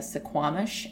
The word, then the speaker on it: Suquamish: And it was the traditional territory of Coast Salish, which is specifically the Suquamish and the Duwamish tribes.